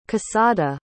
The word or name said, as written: cassada